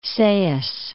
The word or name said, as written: Sais